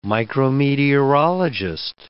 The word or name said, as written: micrometeorologist